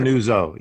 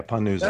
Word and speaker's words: Panuzzo: Panuzzo. Yeah, Panuzzo. – Panuzzo. – You added an extra syllable.